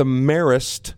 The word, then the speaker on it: Marist: You got to tell me, pronounce the team that we are competing against from New York this weekend. – The Marist Red Foxes.